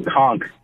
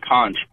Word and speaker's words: conch: what they were eating is actually pronounced conch, not conch.